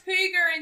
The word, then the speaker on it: Hygge: It's actually pronounced Hygge in Danish.